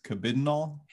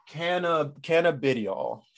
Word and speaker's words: cannabidiol: So the correct pronunciation is cannabidiol. – Cannabidiol.